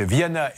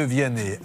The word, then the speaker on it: Vianney: Mais il faut faire attention, hein, parce que si vous dites Viana et Mickey, ils pourraient le prendre très mal. Donc il faut bien prononcer.